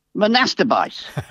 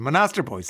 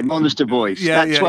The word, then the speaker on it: Monasterboice: Monasterbice.